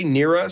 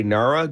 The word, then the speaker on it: NARA: So for those who haven't yet visited our pages, so I say Neura, some people will say Nara. Good news, they're both correct, so don't stress. But it is spelled Nara, as in N-A-R-A community.ca is our website.